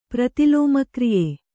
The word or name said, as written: ಪ್ರತಿಲೋಮಕ್ರಿಯೆ